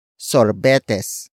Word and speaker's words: sorbetes: It is the object marker that introduces what I bought for myself, and that is sorbetes.